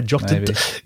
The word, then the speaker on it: JOTD: And this is by, is it J-O-T-D? Is that how you pronounce his name? Yeah, Jotted. Yeah, never know how to say his name.